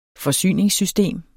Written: forsyningssystem

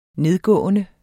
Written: nedgående

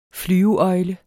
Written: flyveøgle

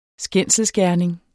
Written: skændselsgerning

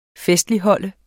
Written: FESTLIGHOLD

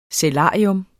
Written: sælarium

